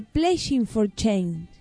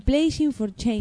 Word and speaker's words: Playing for Change: Chanda Mama de Playing for Change.